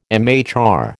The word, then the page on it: MHR: American English